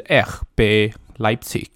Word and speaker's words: RB Leipzig: 那 RB 莱比锡的正确发音是 RB Leipzig，然后它的全文其实不是 Water Bullen，不是 Red Bull，是 Rasenballsport，就是草地球运动。